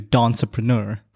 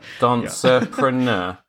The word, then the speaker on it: Dancerpreneur: So it's Dance Entrepreneur. So she made it into Dancerpreneur. So just take out the tr. – Dancerpreneur. The Dancerpreneur Academy.